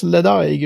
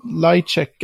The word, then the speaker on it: Ledaig: Det, jag hade väl en, de här som inte uttalas Ledaig utan Lightcheck eller något sånt.